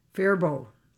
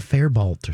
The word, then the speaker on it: Faribault: How do you say that city? – Faribault. – See, that's what Faribault. I said too. – Didn't I say Faribault? – You said Fairbault or something.